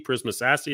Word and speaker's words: Prisma SASE: Prisma SASE, is that how you say it? – Yeah, that's how you say it.